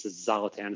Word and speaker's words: Zlatan: I think it's Zlatan is how you pronounce the first Zlatan.